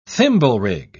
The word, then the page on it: thimblerig: British English